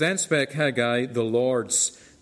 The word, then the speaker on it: Haggai: or maybe some of you pronounce it Haggai. I'm just doing the Northern Ireland pronunciation. We call it Haggai.